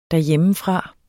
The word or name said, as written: derhjemmefra